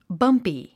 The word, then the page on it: bumpy: bʌ́mpi; バンピィ